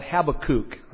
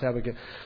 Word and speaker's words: Habakkuk: Some people call it Habakkuk. That's not how it's pronounced, Habakkuk.